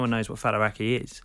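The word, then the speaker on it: Faliraki: Your new show is called Falaraki. – Yeah. You pronounced it correctly. A lot of people don't.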